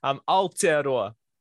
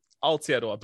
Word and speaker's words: Aotearoa: Aotearoa is the name. Aotearoa, but beautiful pronunciation, beautiful, beautiful attempt. It's a difficult one. There's a lot of different things about Maori pronunciation and how we blend our vowels together and drag out certain things.